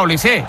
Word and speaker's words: Olise: Es decir, si Mbappé es Mbappé, Dembélé es Dembélé y Benzema es Benzema, pues digo yo que Olise será Olise.